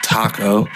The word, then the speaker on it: Taco: My name starts with a T, ends with an O. There's an A and a C between it, and it goes like taco.